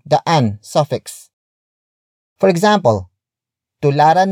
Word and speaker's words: tularan: Tularan natin ang mga bayani. Tularan natin ang mga bayani. The first word is tularan. Tularan. Tularan. Tularan is spelled as T-U-L-A-R-A-N.